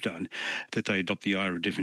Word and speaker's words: IHRA: IHRA is pronounced IRA, but they haven't given it any effect.